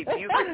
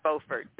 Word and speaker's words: Beaufort: Well, I always joke because North Carolina pronounces the name of the town, even though it's spelled the same way, the wrong way. We say Beaufort, they say Beaufort.